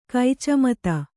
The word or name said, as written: ಕೈಚಮತು